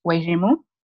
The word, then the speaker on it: Wairimu: Mwalimu, which means teacher, and then my name is Wairimu.